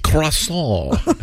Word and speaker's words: croissant: Croissant.